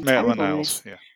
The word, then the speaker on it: Maitland-Niles: I think Maitland-Niles, is that how you pronounce it? Giles. I can't read my own writing, listeners. I'm really sorry. – Yes, Maitland-Niles.